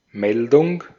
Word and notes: report: American English; /ɹɪˈpɔɹt/

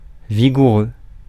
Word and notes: vigoureux: France; [vi.ɡu.ʁø]

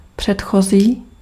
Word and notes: précédent: /pʁe.se.dɑ̃/; France (Paris)

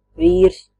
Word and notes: husband: /ˈhʌz.bənd/

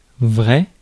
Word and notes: vrai: France (Paris); [vʁɛ]

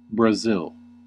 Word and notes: Brazil: /bɹəˈzɪl/

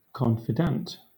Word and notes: confidant: Southern England

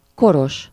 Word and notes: koros: /ˈkoroʃ/

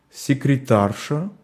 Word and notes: секретарша: /sʲɪkrʲɪˈtarʂə/; sekretarša

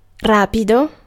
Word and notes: rapido: [ˈra.pi.do]

